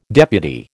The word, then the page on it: deputy: American English